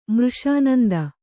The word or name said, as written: ಮೃಷಾನಂದ